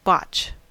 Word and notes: botch: American English

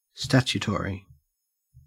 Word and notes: statutory: Australian English

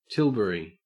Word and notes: tilbury: Australian English

